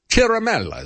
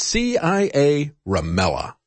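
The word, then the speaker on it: Ciaramella: Chiramella is the way you pronounce it, but it's CIA Ramella.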